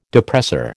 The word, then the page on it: depressor: American English